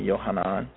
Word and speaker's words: Yohanan: So, if you don't know, that's the Hebrew pronunciation, Yohanan.